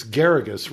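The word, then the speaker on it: Geragos: My mom used to say, it's Garagos rhymes with asparagus.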